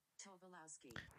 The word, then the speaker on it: Tobolowsky: Tobolowsky.